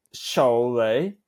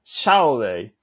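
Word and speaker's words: šalę: But like, at first, but at first I didn't know how to pronounce it, so I was always saying that shaole. – Yeah, on the first, on the first syllable. Yeah, yeah, because in spellings, it's written like ow, so I thought it was pronounced in like shaole, but in reality, like, it's shole.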